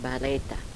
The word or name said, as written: bareta